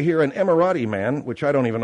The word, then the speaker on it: Emirati: Emirati. – All right, Emirati.